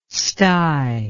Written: sty

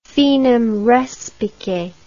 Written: finem respice

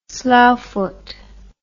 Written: sloughfoot

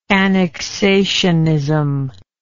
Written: annexationism

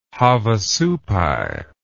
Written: havasupai

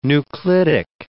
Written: nuclidic

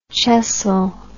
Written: chessel

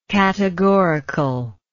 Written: categoricalness

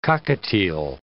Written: cockatiel

